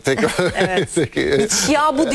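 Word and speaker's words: Bitshiabu: Gündeme gelen isimler arasında Biçavut yoktu. – Yani ismi Batshuayi'ye benziyor. – Biçavu diye yazılıyor ama kendisi söyledi. Biçavu diye okunuyor.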